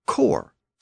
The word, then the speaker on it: corps: We can also see this distinction in words like corpse, C-O-R-P-S-E, and core, C-O-R-P-S. Both words are still spelled with an S, but the pronunciation of the S was lost in core.